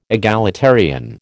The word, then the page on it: egalitarian: American English